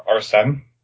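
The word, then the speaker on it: Arsène: The Arsene, I think it's called. Is that how you pronounce it? – Yeah, I believe you're right.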